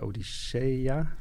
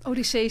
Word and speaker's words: Odyssee: De Ilias en de Odyssea. Spreek je dat zo uit? Of de Odyssee? I don't know. – Odyssee zeg ik altijd, maar okay.